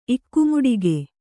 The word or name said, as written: ಇಕ್ಕುಮುಡಿಗೆ